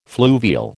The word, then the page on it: fluvial: American English